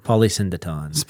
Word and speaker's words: polysyndeton: Polysyndeton.